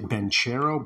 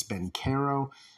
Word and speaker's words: Banchero: I was pronouncing it Banchero, but it's Banchero.